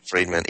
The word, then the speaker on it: Friedman: Friedman, yeah, of the New York Times, a columnist, recently stated that the lobby had bought Congress.